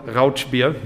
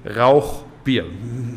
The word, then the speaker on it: Rauchbier: Yeah, it is not Rauchbier. This is Rauchbier. – There you go. – Rauchbier.